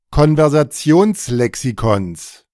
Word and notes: Konversationslexikons: German

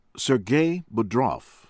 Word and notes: Sergei Bodrov: American English; from Russia

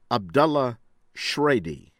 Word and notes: Abdullah Shraidi: American English

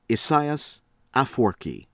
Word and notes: Isaias Afwerki: American English; from Eritrea

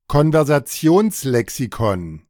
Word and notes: Konversationslexikon: German

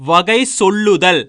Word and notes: வகைசொல்லுதல்: Tamil

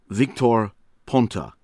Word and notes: Victor Ponta: American English

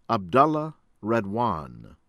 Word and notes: Abdallah Redouane: American English

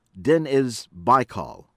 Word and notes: Deniz Baykal: American English

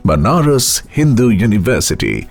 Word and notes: Banaras Hindu University: English

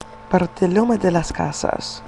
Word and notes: Bartolome de las Casa: Spanish

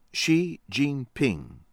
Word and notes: Xi Jinping: American English

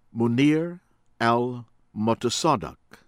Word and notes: Mounir El Motassadek: American English; from Morocco

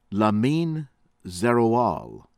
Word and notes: Liamine Zeroual: American English

